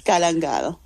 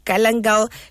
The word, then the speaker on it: galangal: Now, Chef, before we start, am I saying it right? Is it galangal, chicken, or is it galangal? I don't know how to say the word. – It's galangal. – Okay, yes, dear listeners, you've heard it.